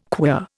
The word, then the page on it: queer: Indian